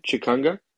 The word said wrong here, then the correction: Changa: Changa, yeah.